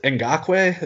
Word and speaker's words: Ngakoue: Ngakoue? Is that how it's pronounced? That's, yeah.